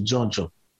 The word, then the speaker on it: djon djon: We grow a variety of mushrooms in Haiti that is called jonjon. Well, the jonjon terms come from the French champignon. So champignon in French means mushrooms. So since they couldn't say the whole champignon, they say jonjon. – How do you spell that? – It's spelled D-J-O-N, D-J-O-N.